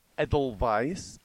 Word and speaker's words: Edelweiss: The word wiener is pronounced with a W sound on its own, but a V sound in Wiener Schnitzel, Edelweiss and Wunderkind have a V sound, Waltz and Wolfram have a W sound.